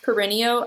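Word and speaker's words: Perrineau: And Harold, I think his last name is pronounced Perennio.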